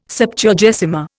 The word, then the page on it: Septuagesima: Indian